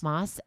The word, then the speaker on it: Maas: It's Sarah, M-A-A-S. – Oh, I don't know. – I don't know either, but Moss.